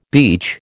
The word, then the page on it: beach: American English